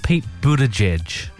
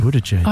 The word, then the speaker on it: Buttigieg: Oh, Pete Buttigieg. – Thank you. – Buttigieg.